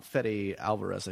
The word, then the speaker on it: Fede Alvarez: Fede Alvarez, I believe I'm pronouncing his name correctly.